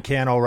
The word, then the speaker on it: Cannell: Sean Cannell, rhymes with YouTube channel on all the platforms. That's S-E-A-N.